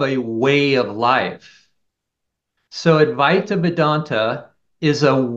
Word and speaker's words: Advaita Vedanta: Advaita Vedanta is.